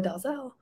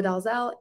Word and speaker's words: Dalzell: My name is Noa Dalzell. My name is Noa Dalzell, and I am a new writer covering the Celtics, started over the summer.